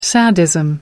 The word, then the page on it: sadism: American English